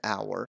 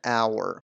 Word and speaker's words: hour: موضوع اینجا پیچیده میشه که ما کلمه ای مثل hour داریم، اول این کلمه h هست ولی ما an گذاشتیم و گفتیم an hour.